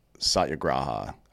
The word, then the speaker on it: Satyagraha: There's this principle, Hindi principle called Satyagraha. I always mispronounce it, but that's how you say it.